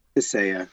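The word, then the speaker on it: Tissaia: I apologize if I fucked that name up. Is that how you say it? – Tissia. – Tissia.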